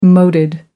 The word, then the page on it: moated: American English